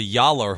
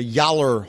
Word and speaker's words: Gjallar: It's called a Yaller horn. – A Yaller horn. – Really? That's what it's called? – Yeah, it's spelled G-J-A-L-L-A-R horn.